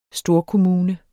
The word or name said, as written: storkommune